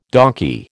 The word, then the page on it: donkey: American English